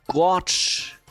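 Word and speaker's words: GOARCH: Laut Go-Erfinder spricht man die Environment-Variablen Goose und Gorge aus, ja.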